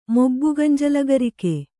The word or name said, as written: ಮೊಬ್ಬುಗಂಜಲಗರಿಕೆ